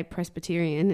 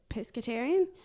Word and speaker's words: pescatarian: Now, I always say it wrong because I always say Presbyterian and everyone's like, no. – Jesus, wrong. – Yeah. – Pescatarian? – Okay.